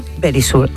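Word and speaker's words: Verisure: Securitas Direct es ahora Very Sure. Eso aprendido, yo creo que ya lo sabéis, pero Verisur, ay, que me lo dice, eso es.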